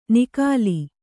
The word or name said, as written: ನಿಕಾಲಿ